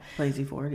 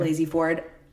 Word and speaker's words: Blasey Ford: I mean, after the Brett Kavanaugh stuff with Christine Blasey Ford. Did I say that right? Yeah. – Blasey Ford. – Yeah. Blasey Ford.